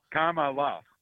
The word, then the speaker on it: Kamala: Say comma. – Kamala. – Kamala. There you go.